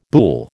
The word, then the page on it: boulle: American English